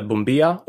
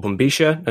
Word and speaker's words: bombilla: It's called a bombilla or bombisha. And there are, you know, various pronunciations of this word.